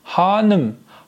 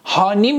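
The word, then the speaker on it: hanım: در ادامه هانم هانم هانیم نیستا هانم.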